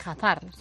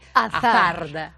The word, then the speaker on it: Hazard: porque dicen que no se dice Hazard, sino que se dice Hazard, vale.